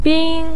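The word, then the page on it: bin1: tone 1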